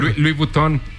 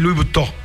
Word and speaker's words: Louis Vuitton: Como Louis Vuitton. – Sí, ese no es Louis Vuitton.